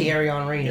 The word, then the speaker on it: Thierry: It's not a common first name, so I'm not sure if you pronounce it Thierry or Thierry. T-H-I-E-R-R-Y. I'm going to guess it's Thierry. – It may be Thierry. I know of a French. – Is it Thierry? – Yeah, Thierry Henry is who I think of.